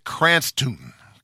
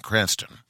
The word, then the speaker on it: Cranstoun: Guy's name is Cy Cranston. I believe that's how you pronounce it. C-R-A-N-S-T-O-U-N, Cranston.